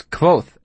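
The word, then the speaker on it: Kvothe: "It's Quoth, actually," I said absent-mindedly. "The E is silent."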